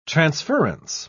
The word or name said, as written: transference